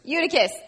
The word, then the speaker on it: Eutychus: Eutychus, thank you.